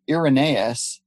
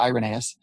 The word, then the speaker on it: Irenaeus: You could go back to, before the year 200, Irenaeus, some people pronounce it Irenaeus, Irenaeus of Lyon in what we now call France.